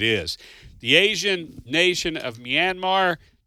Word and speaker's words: Myanmar: I should have got – how do you pronounce that town? – That's Myanmar. – Myanmar. Thank you.